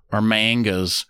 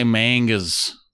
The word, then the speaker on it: manga: So I guess I should say it's based off a comic first, or mangas. It's pronounced manga. I know, nerds. I just like to say mangas.